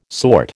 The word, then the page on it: sort: American English